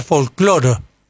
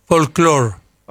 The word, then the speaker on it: folclore: Y acá, hasta que cuando yo decía, decía folcloro. Porque horrible. – Lo pronunciaban sí, más o menos como el inglés. – Claro. – Folclore. Y hace unos 30 años que se dice folclore.